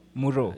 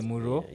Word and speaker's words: Mvro: So far, the releases, I know, I came to realize that there's an artist called Mvro. He was part of... – Maro. Just say it like that. – It's Mvro. – No, Muro. – Yeah, say it like that. – Okay, Muro.